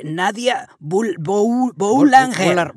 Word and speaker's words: Boulanger: Mira, empezó la temporada hablando, entre otros personajes, de Nadia Boulanger. ¿Sí se dice así? – No, me parece que ni la O ni la R se pronuncia.